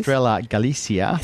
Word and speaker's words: Estrella Galicia: Yeah, so Miller and his team, Estrella Galicia. – I'll pronounce that one again for me, please. – Estrella Galicia.